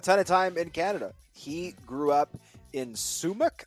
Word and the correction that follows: Sumas: I hope I'm saying that correctly, Washington, which is literally touching the Canada-U.S. border. – Sumas. – It is a Sumas. My apologies.